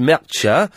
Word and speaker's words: Mertxe: The name M-E-R-T-X-E is pronounced Mercha, and there was something else.